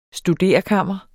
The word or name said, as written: studerekammer